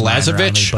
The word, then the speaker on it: Balazovic: Let's make Balazovic. Is that what you said? Is that how I pronounce it? – Actually, in this case, your guess is as good as Balazovic. But that is what I have heard.